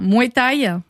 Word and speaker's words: Muay Thai: Alors, est-ce que je prononce bien Muay Thai? – Oui, c'est ça.